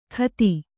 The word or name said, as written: ಖತಿ